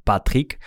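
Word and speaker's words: Patric: Ook pijnlijk verdedigen van Patrick. Die we zo moeten uitspreken, dat weten we tegenwoordig. Dat liet Jurriaan van Wessen me weten in de DM's op Twitter.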